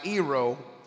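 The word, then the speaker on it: Iro (αἴρω): So It means, the definition of this word here, it's spelled A-I-R-O-O, Iro, and it means to lift up.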